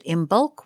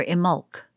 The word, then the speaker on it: Imbolc: Many witches call this holiday Imbolc, and it is always spelled that way, whether you pronounce it Imbolc with the B or Imolc.